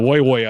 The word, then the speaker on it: Woy Woy: No, Woy Woy, I think is how you actually want to pronounce it.